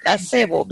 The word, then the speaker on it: Acebo: I say Acebo because it's the Spanish word for holly.